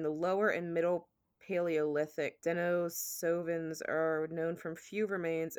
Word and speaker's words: Denisovans: It's spelled D-E-N-I-S. O-V-A-N-S. – All right, spell that for me one more time. – D-E-N-I-S. Penis, but with a D. – Okay, got it. – O-V-A-N-S. So Denisovans?